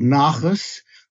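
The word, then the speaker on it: nachas: And of course, anytime my son Jim is mentioned, I burst with that word called nachus. Nachus, you have to learn how to pronounce it with a ch, and that's the Yiddish word, mainly means the pride and pleasure you take in your children.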